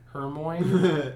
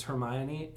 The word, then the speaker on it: Hermione: Before the movies came out and pronounced Hermione Hermoine. And then when they saw the movie, they realized it was Hermione, and it changed it for them.